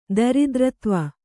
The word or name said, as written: ದರಿದ್ರತ್ವ